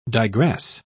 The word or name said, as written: digress